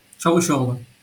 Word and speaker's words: Çavuşoğlu: And this went right over my head, but it was the Turkish Foreign Minister uh, Çavuşoğlu. Am I saying that correctly? – Çavuşoğlu.